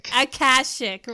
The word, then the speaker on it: Akashic: She's been a teacher, a transformational coach, an Akashic? Is that how do you say that? – Akashic record reader in St. Louis for over 40 years and a prolific writer and presenter.